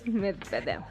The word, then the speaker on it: Medvedev: Medvedev. – Es un apellido difícil, ¿eh? Pronúncienlo en casa, es como un trabalenguas. Medvedev. – Medvedev. – Medvedev. Puedes sacar nada más la lengua poquito y te sale.